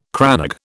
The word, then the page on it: crannog: British English